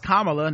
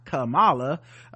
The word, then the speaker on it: Kamala: I really appreciate you doing research on Kamala, pronounced Kamala, not Kamala.